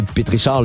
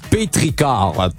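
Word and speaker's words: pétrichor: Bon, c'est pas un pétrichard, là, juste à côté, c'est marqué, ça se prononce pétricard.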